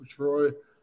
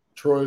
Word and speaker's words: Troye: It's my full name, Christopher Troye. Troye is spelled T-R-O-Y-E.